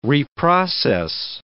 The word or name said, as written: reprocess